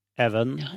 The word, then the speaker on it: Evan: Eller jeg vet ikke om du uttaler Ewan når det er enkel V, eller blir det Evan? Even, Even er det da. Even, mm. Even, ja, ja.